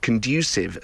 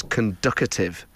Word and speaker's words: conducive: Joe says, my partner and his mum used to pronounce the word conducive as conducative. Oh, come on. There's not even a T in there.